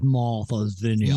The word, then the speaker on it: Martha's Vineyard: You didn't say that right. Martha's Vineyard.